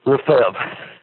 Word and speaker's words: Lefevre: Lefebvre. – Spell it. – L-E-F as in Frank. E-V-R-E.